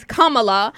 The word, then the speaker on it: Kamala: I pronounce her name the right way. Okay, I just would have put that out there. It is Kamala.